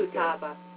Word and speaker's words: Futaba: You pronounce every letter in Japanese, Futaba.